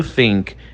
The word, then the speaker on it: think: Galera, dica para a pronúncia do think. É só você colocar a língua entre os dentes. Think. Think. Coloca a língua entre os dentes, como se uma pessoa com língua presa fala, sabe?